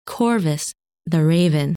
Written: Corvus

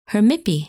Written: Hermippe

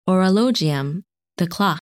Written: Horologium

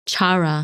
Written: Chara

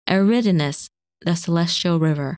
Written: Eridanus

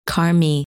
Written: Carme

